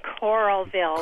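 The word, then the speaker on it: Coralville: No, Corralville. – Corralville. – Coral in the sea.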